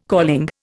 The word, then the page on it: calling: Indian